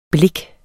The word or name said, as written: blik